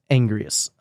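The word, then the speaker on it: Anguirus: I believe it's Angrius. – Angurius. – It's spelled Angurius, but everyone says Angrius if I remember correctly.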